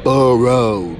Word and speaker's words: Burrow: Let's get his name. Burrow, B-U-R-R-O-W, not B-E-A-U-R-U-A-U. You know how you, Burrow.